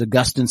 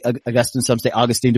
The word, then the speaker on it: Augustine: Augustine, some say Augustine, depends on who's pronouncing. I think smarter theologians say Augustine like you did.